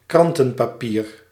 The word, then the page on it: newspaper: [ˈnjuːz.ˌpeɪ.pə]